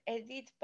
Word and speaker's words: Edith: And as soon as I say it, they're like, oh, you mean Edith? And I was like, no, it's Edith.